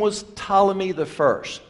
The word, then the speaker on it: Ptolemy: It has a P in front of it, Ptolemy, but we don't pronounce the P.